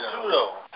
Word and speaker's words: pseudo: And if you're going to give this quota right, what's the word, Suedo, when you say? – Pseudo? – When P-S-E-U-D-O. – Pseudo. – Pseudo, I'm sorry, I didn't know how to pronounce it. I never pronounce it, I only read it.